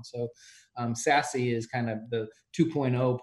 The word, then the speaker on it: SASE: The acronym is SASE. Everybody's pronouncing it SASE.